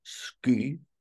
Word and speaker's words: sky: So there's a word, we have a word sky, which is spelled S-K-Y, and in Old Norse there was a similar word, pronounced like that, pronounced ski, which meant cloud, actually.